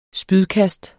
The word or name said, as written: spydkast